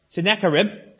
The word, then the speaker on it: Sennacherib: We have this king from Assyria whose name is, and I can never pronounce it, so we're going to tell you how it's pronounced, Sennacherib.